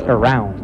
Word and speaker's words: around: You know, you still hear from students, sometimes they say around. But it's around. And it rhymes with drowning.